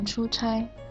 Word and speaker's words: 出差: 我的爸爸在公司工作，经常出差。 – 对，就是这么用。这个出差的“ 差” 和差错的“ 差” 是一个多音字，但是其实这个字啊，不仅有这两个读音，它还有“ 差” 和“ 呲” 的读音。